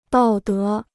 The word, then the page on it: 道德: dào dé